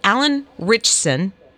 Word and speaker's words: Alan Ritchson: And so this one has Alan Ritchson.